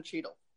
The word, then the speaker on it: Cheadle: And there's this guy, it's based on the main character, it's played by Don Cheadle, or Cheadle, how do you say his name? – It's Don Cheadle. You got it right.